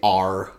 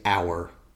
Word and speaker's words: our: And yeah, I don't want to say R anymore. I want to say our. You know? – Yeah, the correct pronunciation of that word.